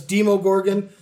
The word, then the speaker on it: Demogorgon: I say Demigorgon, but you go nuts. – I see Demogorgon. – It's Demogorgon, Demogorgon, Demogorgon. There's also, I came across a Demogregan. All right, that was one of the pronunciations I found. – Well, they're wrong.